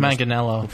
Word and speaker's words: Manganiello: Manganiello took a picture of himself with his shirt off, just completely jacked.